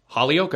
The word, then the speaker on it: Holyoke: Is it Holyoke? – Holyoke, okay.